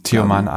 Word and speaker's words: Tioman: Tioman Island.